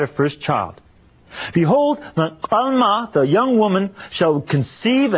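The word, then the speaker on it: alma (עלמה): Now look, a young woman, an alma, that's how you say it in Hebrew, alma.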